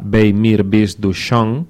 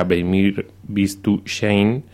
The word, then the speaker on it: Bei mir bist du schön: Sí, estás haciendo la pronunciación germánica del título, porque en realidad el título original era Bei mir bist du schön, que era una canción en yiddish,